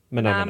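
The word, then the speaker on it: Mah Nà Mah Nà: part of the Austin music experience, that is Calliope Musicals, Sink or Swim, Cake, their version of Mana Mana, or Mana Mana, how would you say it? – Mana Mana. Mana.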